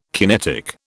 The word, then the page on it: kinetic: British English